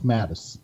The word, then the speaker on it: Mattis: I've never asked, are the A's long in your last name? Is it Matas? – No, it's Mattis. – Mattis. So it's short A's.